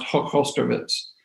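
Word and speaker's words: Hochosterwitz: And the joke I will say is that for those of you who don't speak German, it's pronounced Hochhostowitz, because there is no way to pronounce it otherwise.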